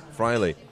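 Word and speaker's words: Fraile: Lewis Angel Matte of Cofidis, Omar Freil, is that how you pronounce his name? – Freile. – Omar Freile, the Dimension data rider, who's been in a lot of breaks this week.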